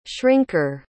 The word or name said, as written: shrinker